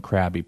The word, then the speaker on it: Crabbe: and Allen Crabbe. I should get some credit for my pronunciation. I think I'm doing a pretty damn good job with this. Well, Crabbe is Crab.